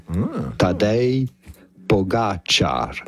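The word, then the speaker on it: Tadej Pogačar: Tadej, you can pretty much consider the J in his first name as an I. Tadej Pogacar. – Pogacar. – You can consider the C with the symbol on it as to be pronounced like a CH. Pogacar. – Pogacar. – You do want to stress on the second syllable of his name.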